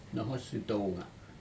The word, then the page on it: nohos itouga: Aneityum